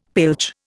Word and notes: pilch: Indian

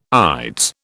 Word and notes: ides: British English

